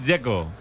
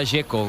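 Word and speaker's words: Džeko: Porque DZ, ¿cómo se pronuncia? – Dzeko. Bruno Dzeko. Dice que es Dzeko. – Como tirando a J Dzeko o algo así.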